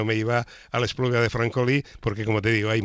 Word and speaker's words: Espluga de Francolí: Pues en tu tierra, la espluga de Francolí, no sé si lo he dicho bien. Sí, ahora la espluga de Francolí, hombre, claro.